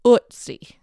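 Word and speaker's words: Ötzi: And then he had a local man come into the frame of the video and he said, show us how the locals pronounce this. And the man said, Ootsie.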